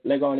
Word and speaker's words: Legon: That's East Legon. Legon is spelled L-E-G-O-N.